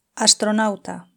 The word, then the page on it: astronauta: Spain Spanish